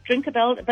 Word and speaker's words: Drinkerbell: So there's a website, Drinkabell, but it's spelled DRINKERBELL, Drinkabell.